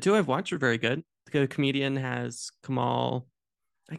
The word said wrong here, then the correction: Kumail Nanjiani: Kumail Nanjiani.